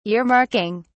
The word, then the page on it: earmarking: British English